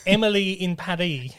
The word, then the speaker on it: Emily in Paris: It's actually supposed to be pronounced in a French accent so that it rhymes. So it's Emily in Paris.